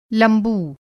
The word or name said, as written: लंबू